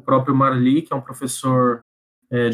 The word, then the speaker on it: Merli: Gente, eu vou encerrar aqui recomendando uma série que tem no Netflix, é chamada Marli, se escreve Merli,